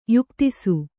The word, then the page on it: ಯುಕ್ತಿಸು: kannada